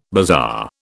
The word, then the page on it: bazaar: British English